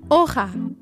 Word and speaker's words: hoja: To say leaf in Spanish, you would say hoja. Would you guys like to try saying it with me? Hoja.